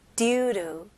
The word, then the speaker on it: due to: 여기서는 due, 그 다음에 to이지만 원어민들은 이 to 단어는 조금 이렇게 약화시켜가지고 너무 to, 센 t 발음보다는 약화시켜서 루, 루. due to 이런 식으로 발음합니다.